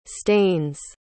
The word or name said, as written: stains